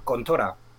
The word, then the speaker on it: Contra: Eh, es que controversia porque realmente este, este juego en japonés se llama Contora. Entonces, ¿cómo? – Contora. contora. Entonces, ¿qué ocurre? Que según, según se pronuncia, sale contra coincidiendo con este comando y, tuvo mucha controversia cuando llegó este juego a Europa después de sacar la, versión de recreativa.